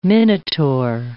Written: Minotaur

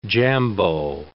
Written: jambeau

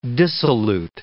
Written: dissolute

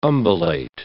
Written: umbellate

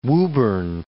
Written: Woburn

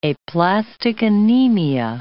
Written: aplastic anemia

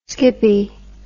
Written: skippie

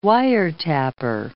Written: wiretapper

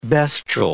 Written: bestial

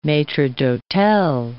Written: maitre d'hotel

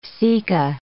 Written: ceca

